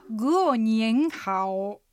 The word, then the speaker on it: 过年好: 発音はひらがなで書くとグオニエンハオというのになります、はい、アクセントはグオが下がる 4 声、ニエンが上がる 2 声、ハオが下がって上がる 3 声、 4 声、 2 声、 3 声の組み合わせです。